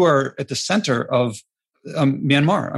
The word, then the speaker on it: Myanmar: How do you say that? Myanmarian? – Yeah, they call it Myanmar, not Myanmarian. So Myanmar person.